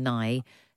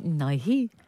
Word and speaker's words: Nighy: I don't think he pronounces it Nighy. I think it's Nye.